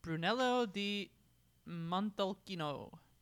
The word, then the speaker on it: Brunello di Montalcino: Well, the next one on the list, sorry, first of all, on pronunciations, Brunello di Montalcino. Yep. Brunello is the name of the local... – Nailed it.